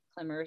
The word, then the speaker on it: Clemmer: So it's just my name, Clemmer, spelled with a C.